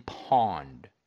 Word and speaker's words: Pwned: It's called Have I Been Pwned? And leave out the A in pawn. It's P-W-N-E-D.